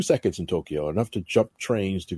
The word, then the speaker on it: Tokyo: Was that Tokyo? Is that how they pronounce it? – No, Tokyo. – Tokyo, yes.